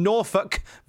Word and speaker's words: Norfolk: I think he's still an incredibly talented guy from Norfolk, Virginia. Don't care what Justin Roberts says, that is how you pronounce it.